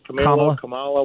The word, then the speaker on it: Kamala: Kamala, whatever.